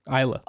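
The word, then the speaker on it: Islay: How do you say it? – Isla.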